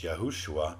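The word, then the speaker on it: Yehoshua (יהושע): And we also call him Yeshua or Yehoshua if we're pronouncing his name the way that the Jewish people used to pronounce it. It's Yehoshua, and it's the same as Joshua, and it's the same name means salvation.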